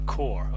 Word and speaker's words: Kaur: So K A U R. K A U R. Core. – Okay, core. Yeah.